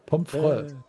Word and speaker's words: Pomfrey: Mit Ä oder mit Ä? – Man spricht es Ä aus. Pomfrey.